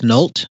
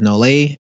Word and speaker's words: Nolet: I am currently sipping on my favorite gin, and I believe it's pronounced Nolt, but other people will probably see it as Nolet, N-O-L-E-T.